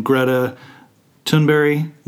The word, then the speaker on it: Greta Thunberg: I believe you pronounce her name Greta Thunberg. – Yes, I think that's right.